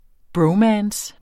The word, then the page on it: bromance: ˈbɹɔwˌmæːns